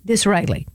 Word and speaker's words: Disraeli: Disraeli, that's who it is.